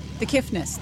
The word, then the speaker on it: The Kiffness: The Kiffness. There's no T. – Sort of like the Prince, or no, Prince, that artist formerly known as? – Well, technically the band is known as The Kiffness, and he is also individually known as The Kiffness.